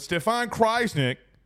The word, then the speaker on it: Stefan Krajisnik: But Stefan Krajisnik, there we go.